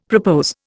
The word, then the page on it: propose: Indian